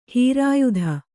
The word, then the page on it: ಹೀರಾಯುಧ: hīrāyudha